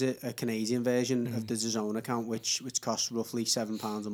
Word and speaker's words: DAZN: So I've signed up to DAZN, or DAZN. DAZN, I'm told reliably that is how you pronounce it.